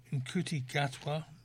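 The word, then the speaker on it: Ncuti Gatwa: Nkuti Gatwa, who's an...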